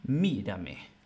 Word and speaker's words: mírame: Por ejemplo, en mírame. No decimos mírame ni mírame, sino mírame, mírame.